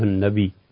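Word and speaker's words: النبيّ: اور اسمع جس شخص کے کان چھوٹے ہوں، یہ اسمعی اسمعی یا مشدد ہے، جیسے نبی اصل تلفظ یوں ہے، سب پڑھتے ہیں السلام علیکم ایھا النبی، اور نبی نہیں پڑھتے، حالانکہ دو یا ہیں، تو اسے تشدید کے ساتھ پڑھنا چاہیے السلام علیکم ایھا النبی.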